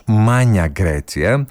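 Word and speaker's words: Magna Grecia: Στα λατινικά να πούμε ότι προφέρεται ως Magna Graecia, ενώ στα ιταλικά προφέρεται ως Μάνια Γκρέτσια.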